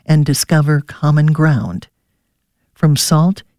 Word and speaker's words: Sowt: It's called Salt, but it's spelled S-O-W-T, and it means voice or sound your voice.